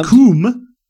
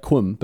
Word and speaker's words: cwm: A coom is a steep-walled semicircular basin in a mountain, sometimes containing a lake. It is spelled C-W-M. – Oh, like Quim, but it's pronounced Coom.